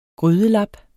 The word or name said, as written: GRYDELAPPER